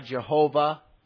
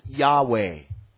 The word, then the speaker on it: Yahweh: And some provide the vowels and pronounce the name of God, Jehovah. Others, like myself, I pronounce it Yahweh.